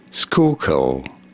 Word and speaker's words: Schuylkill: How do you go about pronouncing it? Schuylkill. Schuylkill River.